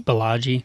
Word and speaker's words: Balaji: I always want to say it Balaji. – Balaji. Like I feel the like Bellagio.